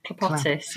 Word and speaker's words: Clapotis: I mean, I'd encountered the effect, but, and is this right? Clapotis? – Clapotis, yeah.